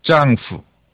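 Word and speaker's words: 짱후 (丈夫): 예, 자 그다음에 남편 예, 남편을 가리키는 가장 대표적인 이제 용어로는요 짱후 예, 짱후 예, 부인 같은 경우는 치즈 치즈 예, 처라는 얘기겠죠 예, 네, 처 치즈 예.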